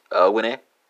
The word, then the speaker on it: winnen: So that's going to be winne, right? Spelled W-I-N-N-E-N for the Dutch, and it's going to be gewinner, spelled G-E-W-I-N-N-E-R. I'm sorry, G-E-W-I-N-N-E-N in German.